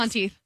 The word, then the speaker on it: Monteith: Monteith.